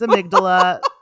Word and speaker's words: amygdala: We just looked it up, how to pronounce it. Pip was correct. It's amygdala.